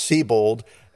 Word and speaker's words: Sebold: I mean, her name is spelled Sebold, S-E-B-O-L-D, but it's pronounced Siebold.